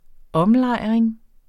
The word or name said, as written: omlejring